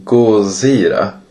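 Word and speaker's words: Gojira: Eh, och eh, som jag sa så man uttalar ju tydligen det här ordet Gozira. Vilket låter ju lite som Godzilla.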